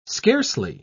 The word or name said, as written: scarcely